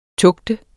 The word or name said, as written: TUGTENDE